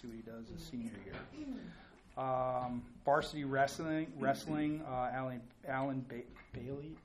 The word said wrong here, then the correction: Bullier: I always get his last name wrong. – Bullier. – No. – Alan Bullier. – It's Bullier? I'm way too phonetic. – Yeah. – All right. – It's French.